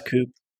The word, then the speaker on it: coup: Pronounce coup. – So that is true. The office I used to formerly call staging a coup, like a chicken coup, until we learned that was wrong.